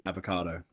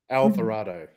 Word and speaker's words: Alvarado: Wallace Stone, John Astin, the amazing John Astin, but I can't pronounce Trini Avocado, so. – She did good. – She's amazing. Trini Alvarado? – Alvarado.